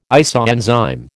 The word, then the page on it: isoenzyme: American English